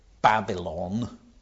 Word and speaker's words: Babylon: We call it Babylon, three syllables, in English. The Hebrew word is just Babel, Babel.